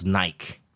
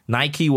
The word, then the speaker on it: Nike: And, you know, the better part of my childhood, to me, N-I-K-E is Nike, right? N-I-K-E is Nike. For a better part of my childhood, I pronounced it Nike. And so did all my friends. Until...I went to a store and everybody's like, Nike, Nike. And then I'm like, what the hell is Nike?